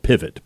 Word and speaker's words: Pivot: It's spelled pivot, but he's French, so Pivot.